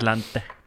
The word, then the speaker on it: Atlante: So I did Google what a team in Cancun is, and it's, would you pronounce it Atlante, would you say? – Like, I don't know. – It's like Atlanta, but with an E on the end. – Atlanta, yeah. – Yeah. Atlante.